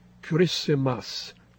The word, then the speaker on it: Kiritimati: this new 846 is from Christmas Island, Kurisimas. It's spelled K-I-R-I-T-I-M-A-T-I, as Brian Clark in New Zealand did, found out with a bit of research.